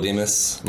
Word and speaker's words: Dimas: Well, on Instagram, at Michael Dimas. Michael spelled weird. It's M-Y-K-O-L D-I-M-A-S.